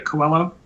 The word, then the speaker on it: Coello: Yeah, Coello.